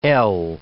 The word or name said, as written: EL